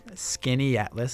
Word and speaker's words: Skaneateles: Skaneateles. So it's spelled like Skaneateles, but Skaneateles. It takes a while for some people to get it,